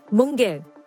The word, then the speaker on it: Munger: A lawyer by training, Munger, rhymes with hunger, helped Buffett, who was 7 years his junior, craft a philosophy of investing in companies for the long term.